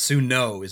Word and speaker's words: Matsuno: And speaking of hex grids and Matsumo, did I just say it right? – It's Matsuno, isn't it? – Matsuno games.